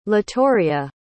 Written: latoria

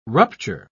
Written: rupture

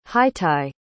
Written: hiti

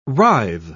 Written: writhe

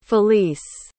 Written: felice

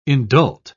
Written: indult

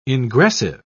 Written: ingressive

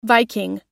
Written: Viking

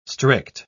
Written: strict

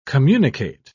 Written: communicate

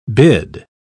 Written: bid

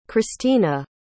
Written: christena

